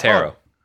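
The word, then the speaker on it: Harrow: It's Haro. – Okay, Harold.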